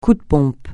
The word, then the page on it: coup de pompe: francais